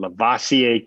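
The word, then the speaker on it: Lovasea: Remember, folks, think like Busta Rhymes when you pronounce that first name. Lavoisier Carroll, one of Busta Rhymes' greatest hits.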